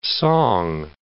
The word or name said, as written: song